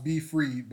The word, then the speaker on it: BFried: It's pronounced BFried, but it's spelled B-F-R-I-E-D, like fried fish, fried chicken, all that good stuff.